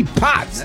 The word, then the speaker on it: Potts: Byron Lee Potts. – That's right. – And Potts is not spelled P-O-T-S. It's spelled P-O-T-T-S.